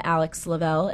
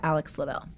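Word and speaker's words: Alix Lovell: My username is I am Alex Lavelle, and my name is spelled with an I, A-L-I-X-L-O-V-E-L-L. I am Alex Lavelle.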